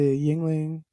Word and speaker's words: Yuengling: It's the Yuengling Lager, right? That's how it's pronounced.